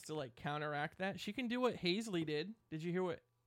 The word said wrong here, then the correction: Halsey: Am I saying her name right? Haisley? Halsley? – Halsey? Are you serious? – Haisley?